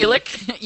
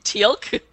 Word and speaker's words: Teal'c: Teal'c? You mean Teal'c? – Yeah, that guy. – You don't say it the way it's spelled.